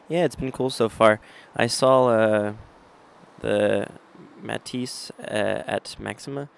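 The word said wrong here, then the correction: Matthias & Maxime: The Matisse at Maxime.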